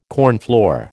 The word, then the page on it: cornflour: American English